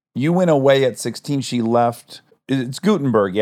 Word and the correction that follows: Gothenburg: yeah? Were you from? Is that how you pronounce it? – Gothenburg. – Gothenburg. – Oh, wow. – Yeah. – I fucked that up bad. I was like, oh, I bet that's a silent H, like Neanderthal, like German. I was wrong.